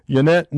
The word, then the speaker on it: Ynet: A major Israeli publication, Yannette News is spelled Y-N-E-T, News, N-E-W-S, Yannette News, disclosed the historical truth about Yagoda, saying, "We mustn't forget that some of the greatest mass murderers of all time were Jewish."